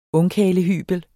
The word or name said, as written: ungkarlehybel